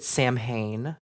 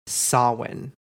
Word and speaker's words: Samhain: And just a note, if you've seen this word, you might have pronounced it Samhain before. Not going to lie, I've read it and used to pronounce it that way until I heard it said, and it is Samhain.